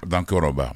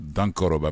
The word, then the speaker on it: Dankoroba: Dankoraba. Voilà, il faut que je prononce Dankoraba. Mais c'est D-A-N-K-O-R-O-B-A. Dankoraba. C'est comme s'il y avait accent circonflexe sur le O, quelque chose comme ça.